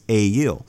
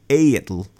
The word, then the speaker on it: Egill: ale's name is E-G-I-L-L. So it should be a-il.